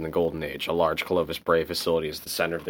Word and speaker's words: Clovis Bray: Clovis Bray. – Clovis Bray.